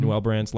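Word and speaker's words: Newell Brands: And I did look at the comparables of the business, and the comparables that I used was, am I pronouncing it right? Noel Brands. Is that how you pronounce it? – Mm-hmm. – Noel Brands, Lifetime Brands, Stanley Black & Decker Inc., and Whirlpool Corporation.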